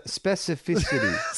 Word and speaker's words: specificity: Speci- no, oh, now I can't do it. Specificity. Speci- specificity. specificity. oh my god! How do you pronounce it? Specificity. – Specificity. Specificity. specificity. That's right.